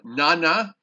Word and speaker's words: Nana: Nana, N-A-N-A, but I believe it's pronounced, the Na is N-A-H, so it's Nana.